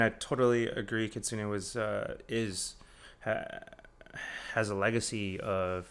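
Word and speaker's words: Kitsuné: Speaking of your label, Kitsune, right? Like Kitsune, that's how you say it.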